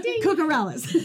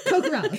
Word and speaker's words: Kokoraleis: You're like, K-O-K. – K-O-K-O-R-A-L-E-I-S. – Cucurellis. – Cucurellis.